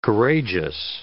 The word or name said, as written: courageous